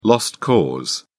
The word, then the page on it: lost cause: British English